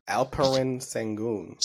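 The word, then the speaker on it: Şengün: Alperen Sengun.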